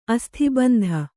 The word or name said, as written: ಅಸ್ಥಿಬಂಧ